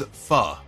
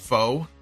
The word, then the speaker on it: pho: It's pronounced as pho. So I stand corrected. It's not foe. I thought it was foe.